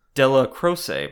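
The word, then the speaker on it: Dellacroce: Aniello Delacroix? Delacroix? – Delacroix. – Della Croce, bro.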